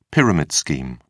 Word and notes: pyramid scheme: British English

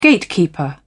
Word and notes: gatekeeper: British English

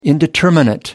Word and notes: indeterminate: American English

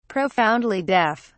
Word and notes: profoundly deaf: British English

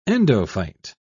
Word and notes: endophyte: British English

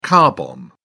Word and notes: car bomb: British English

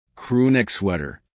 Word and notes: crewneck sweater: American English

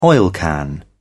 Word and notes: oilcan: British English

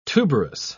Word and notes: tuberous: British English

